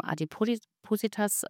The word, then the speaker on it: Adipositas: Adipositas, habe ich jetzt richtig ausgesprochen? – Ja, so richtig, ja.